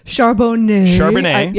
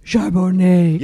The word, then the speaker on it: Charbonnet: My favorite play here is Zach Charbonnet. Am I pronouncing that? – Yeah, you got it. – Charbonnet. Yes, Charbonnet.